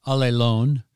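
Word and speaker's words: Alelon (ἀλλήλων): How do you say in the Greek? – Alelon. – Which is weird. One another, it sounds like all alone.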